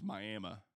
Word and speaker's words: Miami: It's Miami.